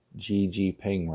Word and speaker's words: Xi Jinping: So, President Xi Jinping, that's the president of China, finally learned how to pronounce Xi Jinping right, hosted the Belt and Road Forum in Beijing over the weekend.